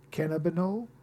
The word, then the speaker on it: cannabinol: C-A-N-N-A-B-I-N-O-L. Cannabinol? – Cannabinol.